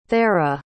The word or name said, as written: thera